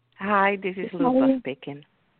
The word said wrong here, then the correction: Luba: Luba. – Could you spell that? Luke? – Luba. L-U-B-A.